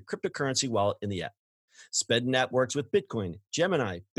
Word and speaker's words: Spedn: Flexa has established a network and launched an iOS wallet app called Speden. It's S-P-E-D-N. I swear I didn't mispronounce that.